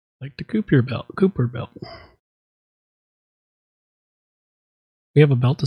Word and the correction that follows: Kuiper: Kuiper. Okay, so it might be pronounced Kuiper. Well, that would make sense. If it's pronounced Kuiper, like the Kuiper belt, then it would make sense.